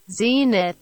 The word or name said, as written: Zenith